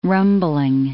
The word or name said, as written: rumbling